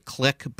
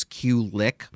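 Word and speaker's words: Qlik: This is from I guess, I guess actually I, know I'm supposed to I'm supposed to pronounce it click, but I, I refuse to. I'm going to pronounce it the way it's spelled, which is Q lick, and apparently Q lick is part of um, Intuit, which is which has uh, QuickBooks, the accounting software.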